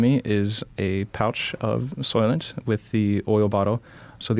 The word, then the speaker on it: Soylent: So we're going to make some Soylent. Is that how you say it? Make some Soylent? We'll drink Soylent? – Make some Soylent.